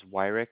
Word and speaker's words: Wyrick: My last name is Wyrick. It's spelled W-Y-R-I-C-K.